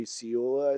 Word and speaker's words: Ciulla: Culla? – Jesse Ciulla, I think is how you pronounce it. – C-I-U-L-L-A? – Yeah.